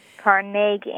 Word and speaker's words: Carnegie: Yes, and if you go to his little hometown in Scotland, that's what you'll hear again and again. – Carnegie, accent on the second syllable. – With more of an A than an E.